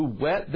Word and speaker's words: whet: Psalm 64:3, who wet their tongue like a sword. It uses the word wet, but it's spelled W-H-E-T, which doesn't mean, you know, like wet, like spit in my mouth.